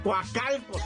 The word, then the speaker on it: Coacalco: Coacalco, no sé cómo se pronuncia, Coacalco. Coacalco, Coacalco se dice, ah, hijo, estoy bien silvestre para esto.